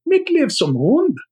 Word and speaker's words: Mitt liv som hund: Of course, Josh wants me to pronounce it in the original Swedish. Mitt liv som hund.